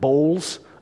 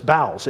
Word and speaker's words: bowels: When I was a child, reading this, I probably pronounced it bowls of mercy. That's not it, though. It's bowels.